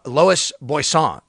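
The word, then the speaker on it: Loïs Boisson: And give Lois Boisson is how you say her name.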